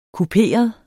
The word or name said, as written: kuperet